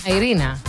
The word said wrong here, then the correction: Irene Cara: No, Irene Cara.